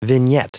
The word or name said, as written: vignette